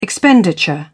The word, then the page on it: expenditure: British English